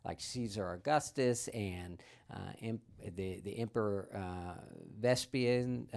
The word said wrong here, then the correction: Vespasian: um, uh, Vespasian, I forget how you pronounce uh, one of the Roman rulers.